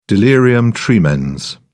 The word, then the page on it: delirium tremens: British English